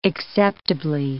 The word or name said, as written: acceptably